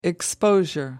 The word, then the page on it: exposure: American English